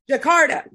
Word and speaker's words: Jakarta: Jakarta.